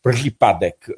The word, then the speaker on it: Przypadek: How do you pronounce it? Przypadek.